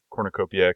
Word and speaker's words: cornucopia: Though I was slightly offended by Sherry's pronunciation of cornucopia because I don't have the y after the N. No. Cornucopia.